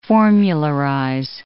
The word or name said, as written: formularize